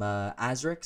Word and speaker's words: Aazriik: I'm Azric, so that's with two A's and two I's. It's spelled A-A-Z-R-I-I-K.